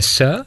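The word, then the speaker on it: se (四): És realment, el títol d'aquest disc és 4, escrit en xinès, es pronuncia se, no és que parli xinès, però he après com es pronuncia 4 en xinès.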